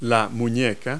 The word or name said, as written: wrist